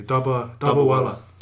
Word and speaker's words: Dabbawala: Well, like, if you're, like, if say for example, that is that job there that, what's it, how you pronounce it again? Dabbawala? – Yeah, Dabbawala.